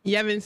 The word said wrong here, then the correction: Evans: Evans. – Evans? – Yes. Well, you see a Y. You saying Yevons. It's an E.